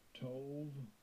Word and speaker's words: Tov: The word good is pronounced this way. – Strong's H2896. Tov. Tov.